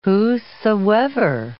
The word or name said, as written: whosoever